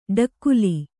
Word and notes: ಡಗಲೆ: ḍagale

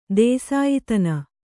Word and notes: ದೇಸಾಯಿತನ: Kannada

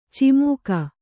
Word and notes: ಚಮಕ: camaka

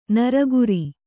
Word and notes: ನರಗುರಿ: naraguri

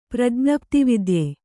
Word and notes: ಪ್ರಜ್ಞಪ್ತಿವಿದ್ಯೆ: Kannada

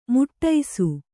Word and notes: ಮುಟ್ಟೈಸು: Kannada

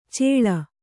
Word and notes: ಚೇಳ: Kannada

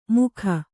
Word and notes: ಮುಖ: mukha

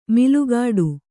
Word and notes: ಮಲಗಟ್ಟು: Kannada

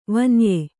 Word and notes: ವಾನೇಯ: vānēya

